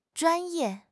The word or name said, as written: zhuan1 ye4